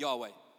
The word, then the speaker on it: Yahweh: God's name, almost always when translated into English, is Lord, all uppercase when you look in the Bible, and but the Hebrew word is pronounced something more like Yahweh, which many of us will be familiar with.